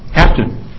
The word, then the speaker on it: hapten: Hampton, can you spell it? – Hampton? – Hapten. – Hapten. Hapten. H-A-P-T-E-N. – H-A-P-E. – T-E-N.